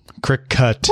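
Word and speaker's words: Cricut: The entire Discord is all over this because I've now been informed that it's pronounced like cricket. – Oh, that makes sense. Yeah. – Yep. – Cri-Cut.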